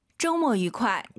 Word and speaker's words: 周末愉快: 周末愉快. – 와, 엄청 빠르게 발음하셨어요. 맞아요. 이 뜻의 특성상 周末愉快 이렇게 안 하죠. 다시 한번. – 周末愉快. – 오유는 어예요. 쪼가 아니라 쩌우, 모가 아니라 모어, 쩌우 모어. 주말 발음 다시 들어보고 싶어요.